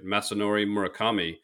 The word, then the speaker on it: Masanori Murakami: And another person in the book is, I hope I pronounce this right, Masanori Murakami.